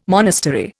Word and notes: monastery: Indian

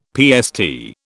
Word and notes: PST: British English

